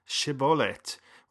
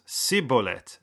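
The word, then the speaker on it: shibboleth: But if the guy wisely says no, then they would ask the individual to say the word Shibolet, which Ephraimites pronounced Sibolet.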